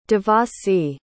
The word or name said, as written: Devassy